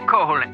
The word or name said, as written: Koholint